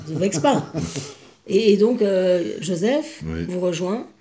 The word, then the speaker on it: Joseph: Et à Istanbul, vous retrouvez votre deuxième fils, Joseph. – Oui. – Joseph. Arrête de prononcer en polonais, ça va pas du tout, là. – Joseph. Joseph.